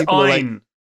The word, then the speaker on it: Ayn: Also, it's Anne. Like, it's Ayn. – But people pronounce it Anne, don't they? – It's Ayn Rand.